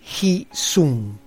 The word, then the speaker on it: hsiung: Peligro, Hi Sung, se escribe H-S-I-U-N-G.